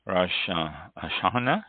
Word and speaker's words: Rosh Hashanah: Oh, today is first day of Rosh Hashanah, is how it's pronounced, Jewish holidays.